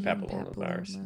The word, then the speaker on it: papillomavirus: I was going to say the whole name, but I never remember how to say it. – Human papillomavirus. – Yeah, sure. I always want to say Pamplona, so.